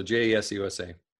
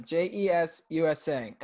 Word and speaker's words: J.E.S.U.S.A.: it's, it's just called JS USA. – You yeah. just say it like you would say USA, just add the JE on the front. So JES USA.